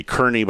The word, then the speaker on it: Kearney: Hey, if you've ever driven I-80 east or west through Kearney, Nebraska, and that's how they pronounce it, K-E-A-R-N-E-Y, looks like it should be Kearney, but they pronounce it Kearney.